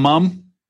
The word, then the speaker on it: Mum: Do you call them mum? – Mum. I know that the Americans, they pronounce it M-O-M. We pronounce it M-U-M here.